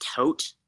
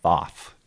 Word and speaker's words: Thoth: I believe it's pronounced Tot. It's a Egyptian god of wisdom. It's the ibis, like head god, whatever. – I'm going with Thoth. T H O T H. – I think that's another way that you can pronounce it, but I've heard it's pronounced Tot as well.